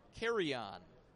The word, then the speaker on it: Carrion: Carrion, C-A-R-R-I-O-N. But pronounced like you're taking a flight.